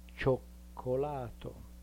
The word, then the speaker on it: cioccolato: So the letter, the sound ch in front of a, cha, will have to be spelled C-I-A, as in ciao. The sound ch in front of o is spelled C-I-O, as in cioccolato. Notice that cioccolato has both C sounds in front of an O. Cho, spelled C-I-O, and then co, and since it's a back vowel, co doesn't have anything in between.